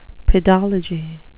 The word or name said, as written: pedology